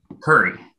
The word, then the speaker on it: Coury: Did I get the name right? Is it Coury? – Curry. – Curry, sorry. Nick Coury.